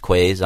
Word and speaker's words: Khue: Yeah, I mean, people call my restaurant Koo's Kitchen. – K-H-U-E. – Yeah, even though it's pronounced Quays.